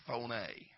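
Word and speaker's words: Phone-A (φωνή): The word sound there in the Greek is spelled P-H-O hyphen N-A-Y. Phone-A. If you take the prefix P-H-O or F-O, phone, you would get such words as telephone.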